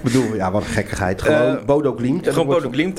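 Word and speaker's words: Bodø/Glimt: Hoe spreek je nou uit? – Gewoon Bodo Glimt. Wij spreken Glimt. Het gewoon op zijn Nederlands of op zijn Rotterdams uit.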